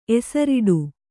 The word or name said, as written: ಎಸರಿಡು